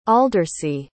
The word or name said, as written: aldercy